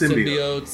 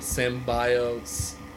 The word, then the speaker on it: symbiote: It's symbiotes. – Symbiotes, it's symbiotes. Sim. It's sim like the game Sims.